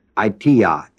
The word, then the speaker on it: aitia: T-I-O-L-O-G-I-A, etiologia. It comes from the word aitia, A-I-T-I-A, and the word logos, L-O-G-O-S. The word A-I-T-I-A, aitia, means a cause or a reason.